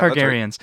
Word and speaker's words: Targaryen: The Targaryen, how do you pronounce? Targaryens, yeah. – Targaryens. – Right. Yep.